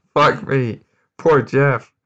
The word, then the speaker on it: Gef: Also, Jeff pronounced G-E-F. Spelled G-E-F.